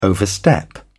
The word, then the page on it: overstep: British English